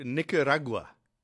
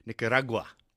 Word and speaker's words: Nicaragua: All right, just before we go to our interview, Nicaragua is how you pronounce it. You put the emphasis on the ra in the middle.